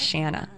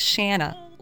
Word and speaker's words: Shana: Well, my real name is Shanna. It's spelled S-H-A-N-A, so people would always say Shana or Shauna. So ever since I was 6 months old, my parents would say, no, it's Shanna, like banana, right?